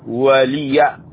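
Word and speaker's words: وَلِيَ: ولي، هكذا تنطق ولي، ولي إذا وقفنا عليها نقف ولي، لكن لا يوجد هنا وقف، ولي دين، ولي ولي، اللام تكسر تفتح الياء،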